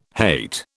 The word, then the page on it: hate: British English